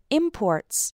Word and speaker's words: import: Now, when we stress the second syllable of the word, export and import, that refers to the action.